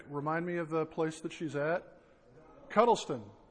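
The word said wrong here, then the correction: Cuddesdon: Cudston. I always mispronounce it.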